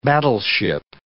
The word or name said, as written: battleship